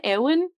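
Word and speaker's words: Éowyn: Eowyn.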